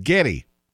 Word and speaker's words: Geddy: His real name is Gary Lee, but she would pronounce it Geddy, Geddy, which sounds like Getty, and so it just stuck, and so Gary or Geddy Lee of Rush is 69 today.